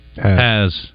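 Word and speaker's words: Hasz: I need to remember, like, has-been.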